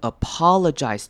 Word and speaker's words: apologize: To sincerely apologize Apologize 这个字也是要特别注意的，它两个 O 的念法不一样，第一个 Apologize 前面的 O 呢是念成短母音 O 的声音，后面 L 之后的那个 O 是念成轻音，所以是念成 Apologize Apologize Apologize Sincerely Apologize Sincerely Apologize。